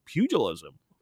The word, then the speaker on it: pugilism: It's pugilism, apparently. I might be pronouncing it wrong. It's pug and then lism.